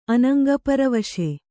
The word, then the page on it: ಅನಂಗಪರವಶ: anaŋgaparavaśa